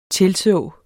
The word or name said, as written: tilså